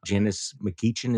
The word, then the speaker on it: McGeachin: The governor of Idaho, Brad Little, beat the insane Trump-endorsed lieutenant governor, Janice McGeachin.